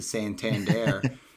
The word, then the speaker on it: Santander: And it's Anthony Santander, who we laugh about because you have to emphasize every syllable in his last name with the Santander.